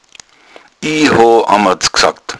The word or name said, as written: amadds